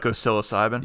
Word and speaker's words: psilocybin: Psilocybin? – Yeah, there you go.